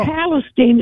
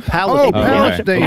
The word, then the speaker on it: Palestine: No, Palestine. It's spelled like Palestine, but Palestine.